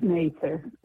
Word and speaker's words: Mather: And it's Mather. That's the way to pronounce Mather.